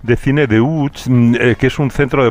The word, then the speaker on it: Łódź: Aunque se pronuncia Łódź, se escribe Lodz, por si alguien lo busca Lodz, en Google.